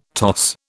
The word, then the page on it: toss: British English